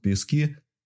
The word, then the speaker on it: Пески: За эти месяцы и он набрался местного жаргона, например, пригород Донецка, поселок Пески, следовало произносить с ударением на первый слог, а не на последний.